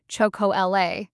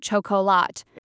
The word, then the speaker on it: chocolat: So chocolat is pronounced chocolat, not chocolat.